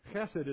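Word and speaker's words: Chesed (חסד): The word for mercy there is the word hesed. Chesed is how they pronounce it.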